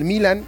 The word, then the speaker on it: Milan: Santiago Jiménez de Milán, exactamente, de Italia, del Milan, o Milán, como lo quieran pronunciar.